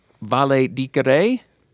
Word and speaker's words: valedicere: The word valedictorian is derived from the Latin phrase valedicere. I did not take Latin, so I don't know if I said that correctly. – I did. That was awesome.